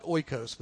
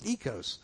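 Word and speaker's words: ekos (οἶκος): It's spelled in English, transliterated. It looks like oikos, but it's ekos.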